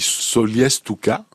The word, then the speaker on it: Solliès-Toucas: On dit Solies Touka, mais il se trouve que quand les, les Toucassiens veulent parler de plus rapidement de, du village, ils disent Toucas. Et quand ils disent pas Solies devant, alors le, à ce moment-là, ils prononcent le S final.